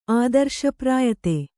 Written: ಆದರ್ಶಪ್ರಾಯತೆ

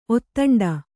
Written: ಒತ್ತಂಡ

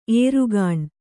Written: ಏರುಗಾಣ್

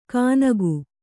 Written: ಕನಗು

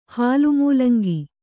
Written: ಹಾಲುಮೂಲಂಗಿ

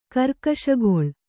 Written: ಕರ್ಕಶಗೊಳ್

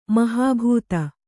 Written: ಮೊಹಬತ್

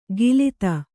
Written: ಗಿಲಿತ

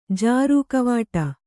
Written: ಜಾರು ಕವಾಟ

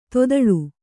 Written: ತೊದಳ್

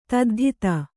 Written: ತದ್ಧಿತ

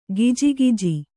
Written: ಗೊಜಗೊಜ

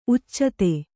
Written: ಉಚಿತ

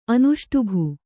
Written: ಅನುಷ್ಟುಭು

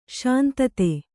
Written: ಶಾಂತತೆ

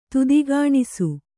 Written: ತುದಿಗಾಣಿಸು

